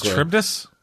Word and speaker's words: Charybdis: I think it's Charybdis. Charybdis? – Charybdis.